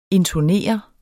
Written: intonere

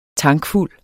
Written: tankfuld

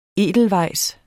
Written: Edelweissen

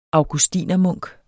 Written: augustinermunk

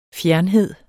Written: fjernhed

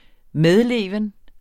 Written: medleven

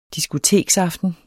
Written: diskoteksaften

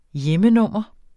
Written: hjemmenummer

